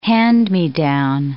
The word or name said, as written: hand-me-down